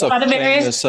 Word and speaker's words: Stradivarius: Okay, it's a Stradivarius. Is that I'm saying it correctly? But it's Stradivarius. – That's Stradivarius.